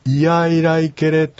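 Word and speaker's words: イヤイライケレ: で、このありがとうございますの場合は、いやいらいけれというふうにきちっとイを発音しますので、ここだけを気をつけていただけるときちっとアイヌ語として通じます。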